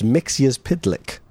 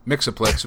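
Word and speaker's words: Mxyzptlk: It is pronounced Mixia's Pidlick. – Mixaplix, which is what I've always said and will continue to say.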